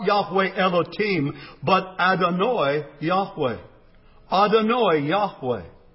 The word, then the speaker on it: Adonai: The third term is Adonai, or as it is commonly pronounced in English, Adonai.